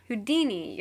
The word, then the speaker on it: Houdini: That's not how you say it. – Houdini? – Houdini, yeah, oh my god.